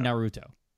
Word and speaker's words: Naruto: I've never seen Naruto. – Naruto. – It's the accent's on the first syllable. At least in my head it is.